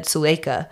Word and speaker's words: Suleika: I know her first name is said Suleika.